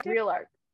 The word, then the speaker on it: ReelArk: Did I say that right? – ReelArk. – ReelArk. – Yeah, ReelArk.